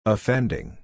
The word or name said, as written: offending